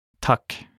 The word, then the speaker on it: takk: In Norwegian, thank you is takk. Takk. Let's break it down by a syllable. Takk. Now let's hear it one more time.